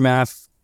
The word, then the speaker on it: Maff: So my name's Andrew Maff.